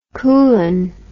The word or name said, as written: koulan